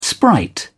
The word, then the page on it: sprite: British English